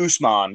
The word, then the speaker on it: Usman: Is it Usman? Am I saying that right? – Usman, yeah.